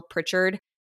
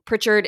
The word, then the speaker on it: Pritchard: Pritchard. Paige is with an I, and then Pritchard is spelled P R I T C H A R D.